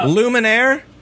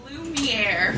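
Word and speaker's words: Lumière: Luminaire? See? – The Lumineers? Ho, hey? – Oh, God. – Candlestick. In it's Lumiere. – Lumiere. See, Chris didn't know how to say it either. Everyone says it with a question mark afterwards. – Lumiere? I can't even remember. Lumiere. That's, yep.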